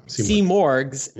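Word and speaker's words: Simurgh: Well, that's interesting because I was actually learning about Seamurg's, or Simorg's, how do you pronounce that? – Seamurg's, yeah.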